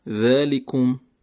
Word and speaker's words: ذالکم: خب در این عبارت ما حرف ذال رو داریم، ذالکم که باید درست تلفظ بشه، نوک زبان به نوک دندانهای بالا.